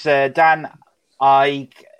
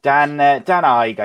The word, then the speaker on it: Dan Ige: So next fight was Dan Ike. I always pronounce his name wrong as well. I'm terrible Ike. – With names. Iggy. Yeah. Dan Ige, I think it is, versus Edson Barboza.